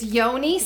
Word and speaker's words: Yoni: Was it you being Yogi Steam? – It's Yoni Steam.